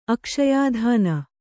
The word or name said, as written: ಅಕ್ಷಯಾಧಾನ